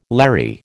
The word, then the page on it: Larry: American English